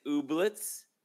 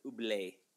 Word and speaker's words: Oblates: It's written Oblitz. – Is it? I've never actually heard somebody say it, so I don't know for sure. We all know the correct pronunciation is Oblé. – Oblates. – Canadian, it's Oblé.